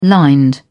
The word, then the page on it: lined: British English